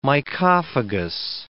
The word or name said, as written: mycophagous